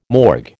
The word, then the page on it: morgue: American English